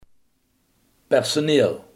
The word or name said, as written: personeel